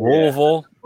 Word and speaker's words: Louisville: I always have to remind myself it's not Louisville. – Yeah, it's Louisville.